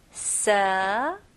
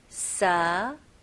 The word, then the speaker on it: severe: 네, 여기서는 V 발음 해주셔야 돼요. 그래서 입술이 붙으면 안 되고 제 입 모양을 보세요. Severe. Severe. 네, 여기서 스펠링은 S-E이지만 발음은 severe. Severe. 그리고 R 발음까지 마무리 잘 해주셔야 됩니다.